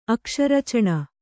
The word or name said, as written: ಅಕ್ಷರಚಣ